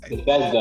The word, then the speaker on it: Bethesda: Bethesda.